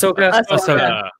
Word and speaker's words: Ahsoka: Is it Ahsoka? – Yes. – Ahsoka. People usually trend the wrong one.